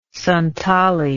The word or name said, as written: Santali